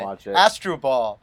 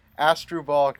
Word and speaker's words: Asdrubal: but then I can't remember how did he pronounce Asdrubal Cabrera. It was really funny. Astroball, that's how he pronounced it. Asdrubal Cabrera.